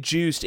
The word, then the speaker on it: Joost: Uh, uh, Reds former uh, baseball player manager Edwin David Juiced, aka Eddie, spelled J O O S T, Juiced, not Eddie.